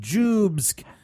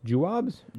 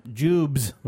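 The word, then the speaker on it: Joobs: And then the article says, how does one pronounce Jubes? Like that, Jubes. – How's it spelled? – Jew. – Okay, with a B-S? – O-O-B-S. – Jew, O-O-B-S? Jewobs? – Jubes.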